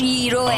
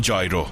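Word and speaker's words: gyro: دارم بهت میگم جو، تلفظش ییروه. – آره، اما تو نیویورک بهش میگیم جایرو. یونانیه.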